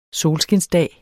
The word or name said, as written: solskinsdag